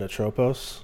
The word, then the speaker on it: Atropos: Clotho, Lachesis, and Atropos.